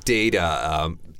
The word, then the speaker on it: data: So again, data, even though there's a T in there, in North America, we tend to say data.